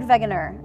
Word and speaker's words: Wegener: Alfred Wegener. It's spelled with a W, but since it's German, it's pronounced with a V.